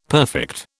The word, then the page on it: perfect: British English